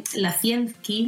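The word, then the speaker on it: Łazienki: Y nos fuimos hacia un sitio que a mí me encantó, y es el Parque Lacienki, me parece que se pronuncia.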